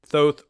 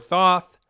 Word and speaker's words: Thoth: Thoth or Thoth, and why the invocation of Thoth or Thoth is, depending on how you say it, both are correct, actually, but why the invocation of this entity is probably a safe ritual.